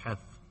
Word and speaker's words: Heth: Again, every section is named after, or it's divided by the letters of the Hebrew alphabet, and this C-H-E-T-H, the C would not be pronounced when it's in Hebrew. It sounds like a guttural sound, heth. And so it sounds like our H, comparable.